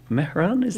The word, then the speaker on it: Mehran: Maya was born in Iran, in a town called Mehran, that's right, yeah, very good pronunciation, well done.